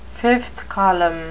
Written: fifth column

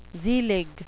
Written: zelig

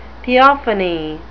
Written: theophany

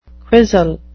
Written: quisle

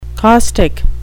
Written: caustic

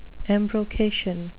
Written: embrocation

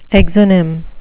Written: exonym

